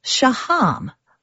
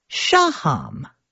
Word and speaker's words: Shaham: Gil pronounces his last name Shaham, but Orly pronounces her last name Shaham. Both are apparently correct and just up to the choice of the speaker, so the two siblings have chosen different places to put the emphasis on the syllable.